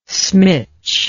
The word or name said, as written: smitch